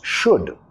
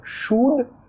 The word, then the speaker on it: should: ¿Cómo pronunciamos should? Primero tenemos sh, sh, sh, como sh. Más el sonido vocálico, acá muchos dicen should, ud, como dando un besito, no quieres. Sh, El u es un sonido que no existe en español.